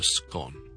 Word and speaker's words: scone: 通常很多人叫它正确的发音呢，其实是 scone，好像 S 音之后我们广东话很好干或者好湿嗰个干咁样，就 scone。